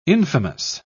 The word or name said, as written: infamous